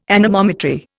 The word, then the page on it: anemometry: Indian